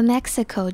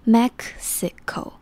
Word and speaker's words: Mexico: Crashing into what is now Mexico's Yucatan Peninsula，这个 Mexico 就是墨西哥这个国家的英文，那它中间有个 X，很多人可能不知道怎么发音，但它其实是 Mexico。这样拆完之后再组起来应该会比较容易理解。 Mexico，合起来 Mexico。